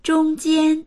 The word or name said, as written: zhong1jian1